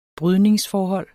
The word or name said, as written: brydningsforhold